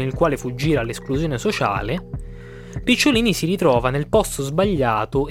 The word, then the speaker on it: Picciolini: Picciolini, Picciolaini, come dicono gli americani. Nessuno riesce mai a pronunciarlo come si deve questo cognome,